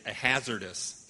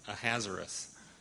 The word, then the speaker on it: Ahasuerus: So I used to say Ahasuerus king, so it's Ahasuerus.